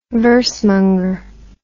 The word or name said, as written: versemonger